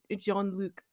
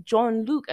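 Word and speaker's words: Jean-Luc: No, no, it was just that I was confused that they were pronouncing it John Luc as if it was spelled in the, in, in the English version when it's a full on French name.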